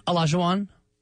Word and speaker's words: Olajuwon: The dream shake from Hakeem the Dream, Olajuwon.